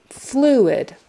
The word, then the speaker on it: fluid: Fluid is spelled F-L-U-I-D, but you say it as if it were F-L-E-W-I-D. Fluid, like the bird flu, and then id, fluid, fluid. With the accent on the first syllable, flu, fluid.